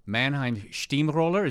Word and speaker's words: Mannheim Steamroller: Mannheim Steamroller? Is that how we should pronounce it? – Sie werden staunen, yes, yeah. Which is German for you'd be surprised.